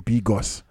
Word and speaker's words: Bigos: Und das, ich habe im Internet geguckt, also ich kann es auch nur als Bigos, aber ich habe geguckt, wie ist das ausgesprochen, habe mir das so vorsprechen lassen, Bigos.